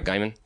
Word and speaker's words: Gaiman: I'm reading American Gods by Neil Gaiman. – Oh, love the shit out of that. – I've never read anything by. And did I say the last name right? Gaiman.